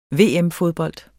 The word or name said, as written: VM-fodbold